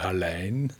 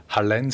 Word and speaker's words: Hallein: Also vielleicht Hallein, also muss man auf die Betonung aufpassen, habe ich das letztens im Radio gehört, Hallein. Viele sagen Hallein, aber Hallein sagt man. Okay. Ich habe es immer falsch gesagt.